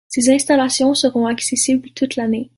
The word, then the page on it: installations: French